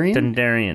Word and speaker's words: Dondarrion: Dondarrion.